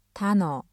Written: 단어

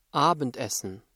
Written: Abendessen

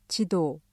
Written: 지도

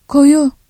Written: koyu

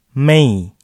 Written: Mei

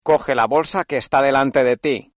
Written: delante

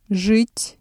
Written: жить

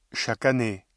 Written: chaque année

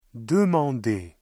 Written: demander

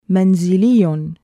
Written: مَنْزِلِيٌّ

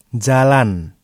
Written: jalan

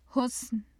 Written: husn

